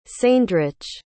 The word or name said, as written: ceindrych